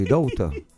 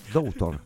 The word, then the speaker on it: daughter: Si pronuncia così, daughter. Yes, daughter. In inglese perfetto.